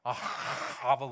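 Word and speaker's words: ahava (אהבה): That ahava love that I mentioned in Deuteronomy, ahava love, okay? That's how it's pronounced in Hebrew, by the way.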